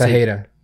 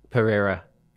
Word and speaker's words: Pereira: Wait, how do you say his last name? I just Pajeda. – Pereira. – Yeah. You could say that.